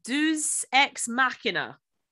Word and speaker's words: deus ex machina: Uh, deus ex machina. – Yes. Have I said that correctly, Natalie? Completely.